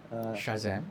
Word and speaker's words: Shazam: Sajam? Is it Shazam? How do you pronounce that? – Shazam.